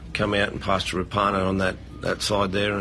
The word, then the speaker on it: Rapana: We've learned in recent weeks that the actual correct pronunciation is Jordan Rapana.